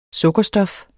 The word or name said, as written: sukkerstof